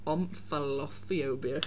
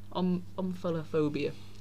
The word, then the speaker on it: omphalophobia: Again, that was the poor pronunciation of it. It's OMF, omphalophobia.